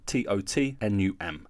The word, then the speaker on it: Tottenham: If you listen carefully, it's pronounced Tottenham. Tottnum. So nearly half the letters are removed when we say it out loud, which is, I imagine, quite frustrating.